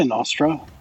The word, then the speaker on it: Nostr: Let's jump onto how do you say it, Nostra or Nostra? – I say Nostra. – Nostra. – But I'm a little I sometimes pronounce it differently. I think I in my head I hear Nostra. – Nostra. – Sometimes I get lazy and drop the tr at the end.